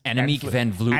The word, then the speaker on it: Annemiek van Vleuten: Van Vleuten, who won, correct? – Yeah. Yes, Annemiek van Vleuten.